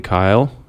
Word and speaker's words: Keil: So you can Google Charlie Kyle, it's spelled K-E-I-L, to learn more about his philosophies.